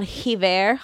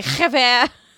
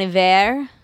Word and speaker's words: Iver: I was like, Hiver. – Very that's the whole thing, is the H isn't pronounced, so that's why he can remove it. It doesn't change the pronunciation.